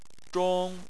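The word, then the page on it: zhong: tone 1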